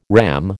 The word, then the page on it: RAM: American English